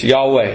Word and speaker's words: Yahweh: But in Hebrew, if we were to pronounce it, it's Yahweh.